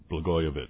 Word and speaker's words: Blagojevich: One of the things, let's go to the daughter-in-law first, and we'll go back to Blagojevich. Is that how you pronounce his name? – Yeah, well done.